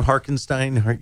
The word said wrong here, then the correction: Hartenstein: Hartenstein. – Hartenstein.